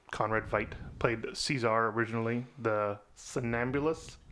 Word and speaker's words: Veidt: Veidt. I say Veidt because it's spelled the same way as Veidt from Watchmen. – Yes, you are correct.